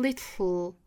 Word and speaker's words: little: 注意英式和美式的区别，英式呢读成 little, 送气，美式会把这个 t, 浊化成 d 的发音，当然不是舌尖在上齿龈的部分去浊，而是往后轻弹一过，同样去找那个 flap t 的感觉，一弹而过，一闪而过。